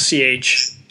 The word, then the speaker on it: Chartres: Now, Chart is spelled Chartres. – Chartres. – R-E-S.